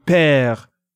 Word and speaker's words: père: Or as in père, P-E accent grave, R-E, father.